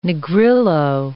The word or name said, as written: Negrillo